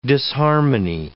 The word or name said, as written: disharmony